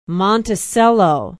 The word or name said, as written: monticello